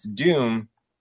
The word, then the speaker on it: Dume: Uh, so I, I, had to put on the closed captions just to, to just to check, and it's it's Doom, but it's spelled D U M E, which is huh.